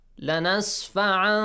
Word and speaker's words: لَنَسْفَعًا: How do you say this? لَنَسْفَعَنْ بِالنَّاسِيَةِ. لَنَسْفَعًا بِالنَّاسِيَةِ. نَسْفَعًا. Right? Say this ayah.